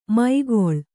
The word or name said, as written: ಮೈಗೊಳ್